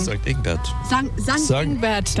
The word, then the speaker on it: St. Ingbert: Und nach Stingbert wollte ich noch. – St. Ingbert. – Ja. Ist das? Oder habe ich den Punkt wohl übersehen? – St. Ingbert.